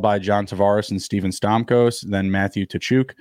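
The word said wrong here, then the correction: Tkachuk: Tachuk, Kachuk, as Nick likes to tell me. The T is not pronounced.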